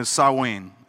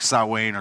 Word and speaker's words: Samhain: And it was a holiday called Samhain, uh, Samhain is I believe how you pronounce it, but it's spelled like Sam Hain, but it's pronounced like Samhain or Samhain.